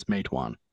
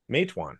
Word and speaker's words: Matewan: Matewan. – Matewan.